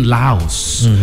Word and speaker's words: Laos: The cave in Laos. I think the S is silent when you actually hear the native folk. Yeah, it's Lao. There is no pronunciation on the S, but either which way, Lao was the place where the cave survivors basically huddled together in absolute darkness.